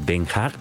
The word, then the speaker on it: Den Haag: La Haya, o Den Haag, como se pronuncia allá,